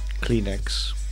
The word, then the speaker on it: Kleenex: Kleenex.